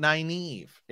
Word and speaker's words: Nynaeve: Nynaeve. – Right. Some people don't even like that we call it Nynaeve.